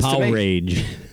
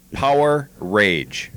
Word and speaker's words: Powerage: I've always said Powerage. Power. I say it like it's two words, even though, yes, the R is shared. – Yeah, that's definitely not that. So. – Nah. It is Power Rage. – Power Rage.